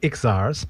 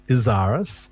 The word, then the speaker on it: Izaris: Now, it looks like Ixaris, but I have it on good authority that it's pronounced Izaris, and she's out of Brisbane.